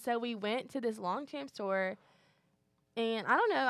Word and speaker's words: Longchamp: And then this one stood out to me because when we were in New York, we went to the Longchamp store. Am I pronouncing that right? – Mm-hmm.